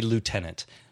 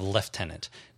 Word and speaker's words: lieutenant: Which would be lieutenant instead of lieutenant. Now, the thing about this is I went and looked that up, and apparently lieutenant is traditionally the British and Commonwealth pronunciation. – Lieutenant. – And lieutenant is the American.